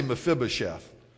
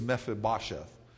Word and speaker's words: Mephibosheth: And I always say Mephibosheth, but if you want to say it correctly, it's Mephibosheth. Mephibosheth.